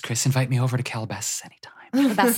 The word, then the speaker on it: Calabasas: Calabasas and... – Say it right. Calabasas. – Calabasas.